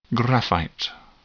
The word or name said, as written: graphite